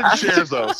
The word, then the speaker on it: scherzo: Everyone in the class is so I said scherzo, so I'm sure you're right. Yeah, sounds like Cher and Alonzo Mourning or something.